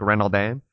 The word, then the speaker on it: Renaudin: Like a lot of people say, like I say Renaudin, like as if I'm some Americanized person, but it really should be like Renaldin. And like when I work with French people for my full time job, that's how they'll pronounce it.